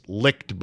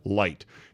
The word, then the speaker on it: Licht: But good for Jason Light, who is spelled L-I-C-H-T. It's licked, but it's really pronounced Light.